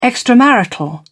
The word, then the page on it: extramarital: British English